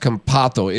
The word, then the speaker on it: Campazzo: No, it's pronounced Campazzo. It is Campazzo.